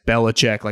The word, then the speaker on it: Belichick: Maybe Mike Vrabel, kind of like Belichick, just figures out, when I say Belichick, like it's three different syllables, just a way to get it done.